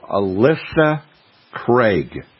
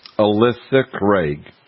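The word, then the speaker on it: Ailsa Craig: It's called Alyssa Craig, and it's spelled AILSACRAIG, Alyssa Craig.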